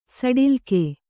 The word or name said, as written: ಸಡಿಲ್ಕೆ